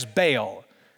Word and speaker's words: Baal: Baal. Now, if you're a Hebrew scholar, you know that the actual pronunciation is Baal, but I'm a Southern scholar and it goes by Baal, okay?